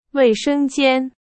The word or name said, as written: 卫生间